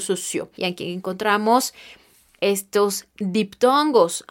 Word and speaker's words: sociaux: Y enseguida tendremos sociales, pero se escribe de la siguiente manera en francés. S, O, C, I, A, U, X socio.